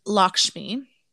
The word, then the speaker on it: Lakshmi: Pronounced Lakshmi, this beloved deity's name derives from the Sanskrit word for goal.